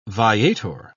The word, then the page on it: viator: British English